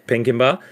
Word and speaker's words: Pinkenba: Is it Pinkenba? – Pinkenba.